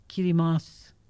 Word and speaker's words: Kiribati: Did you know that that is pronounced Kirimas? Why? I do not know. But that is Kiribati? – How they... Yeah, it's pronounced Kirimas.